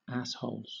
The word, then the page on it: assholes: British English